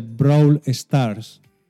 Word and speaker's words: Brawl Stars: ¿Qué es eso del Brawl Stars? ¿Lo he dicho bien? – Sí. – Vale, vale. – Bueno, más o menos.